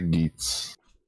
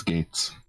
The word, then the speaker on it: Geats: So yeah, I would say Geets, or not Geets, Gates.